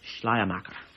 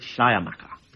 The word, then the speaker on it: Schleiermacher: Well, after Kierkegaard, we have can you pronounce it correctly for me? Schleiermacher. Okay, Schleiermacher.